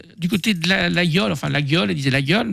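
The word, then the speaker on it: Laguiole: Nous sommes allés aussi passer une semaine à L'Aïole, pas la gueule. Hein. On prononce L'Aïole hein, pour les autres.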